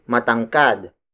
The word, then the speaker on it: matangkad: Tangkad is spelled as T-A-N-G-K-A-D. It means to have a slim and tall body. As an adjective, we say matangkad. Matangkad. Matangkad. Matangkad is spelled as M-A-T-A-N-G-K-A-D.